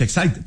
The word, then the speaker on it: Exide: se escribe Exide, se pronuncia Exide, dentro decía la...